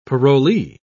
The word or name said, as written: parolee